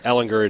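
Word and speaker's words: Ehlinger: Can we settle on the pronunciation of his name? – You say what you think it is, and we'll go with it. – It's Sam Ellinger. – Ellinger it is.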